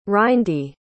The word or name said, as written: rindy